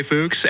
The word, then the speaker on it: Fuchs: You're not the first person to say Fuchs. And if you go to Germany, it's probably closer to the pronunciation of where the origins of my name come from.